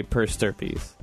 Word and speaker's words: per stirpes: And you want it to be, quote-unquote, split evenly amongst your three kids, you would say per stirpes. – Stirpes? Yes. I was saying it wrong? – Yes.